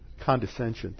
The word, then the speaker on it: condescension: Some writers say this is language of condensation, where the eternal God, I didn't even say that right. Condescension, thank you. My tongue got tangled in front of my eye teeth and I couldn't see what I was saying. What was that again? condescension.